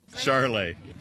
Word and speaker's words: Charolais: Charolais.